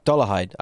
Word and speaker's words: Dollarhyde: Dollarhyde.